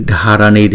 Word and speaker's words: Dharani: Chữ Dharani thì chúng ta đọc là chữ D và chữ H ghép lại chúng ta đọc là D. Cho nên chữ này chúng ta đọc là Dharani.